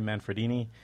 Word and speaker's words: Manfredini: Harry Manfredini. Did I pronounce that right? Yeah, Harry Manfredini.